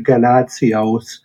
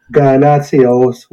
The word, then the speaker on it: Ganatsios: I guess you can pronounce it Ganatsios. – Okay. – It's a Greek name, obviously. – Yep. – I'm not going to say it the Greek way, but Ganatsios would be probably the best way to break it down.